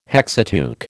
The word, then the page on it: Hexateuch: American English